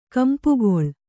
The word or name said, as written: ಕಂಪುಗಾಳಿ